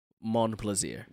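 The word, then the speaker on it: Montplaisir: I think it's supposed to be M-O-N-T. – M-O-N-T, plaisir? – Mm-hmm. – Okay, Mont-plaisir. – No, you wouldn't pronounce the T, it's French. – Mont-plaisir.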